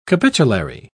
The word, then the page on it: capitulary: British English